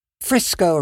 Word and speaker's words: Frisco: Frisco rhymes with Crisco.